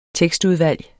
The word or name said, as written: tekstudvalg